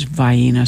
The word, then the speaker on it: Vienna: As you may remember, that was featured in this poem, but I said Vienna because it's a hard thing for me to say is Vienna sausage, but that is the accurate Southern pronunciation.